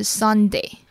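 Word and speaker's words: sundae: 而圣代它的英文是 Sunday。听起来是不是就跟我们一个礼拜当中的星期天 Sunday 发音非常的像呢？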